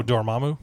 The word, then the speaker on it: Dormammu: name oh Dormammu yeah Dormammu, yeah.